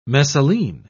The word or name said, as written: messaline